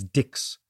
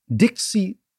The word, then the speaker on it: Dix: So the English speakers looked at Dix and pronounced it phonetically as Dix, whereas the French pronounced it Dix, right? Mm. Dixie became the place where that currency circulated.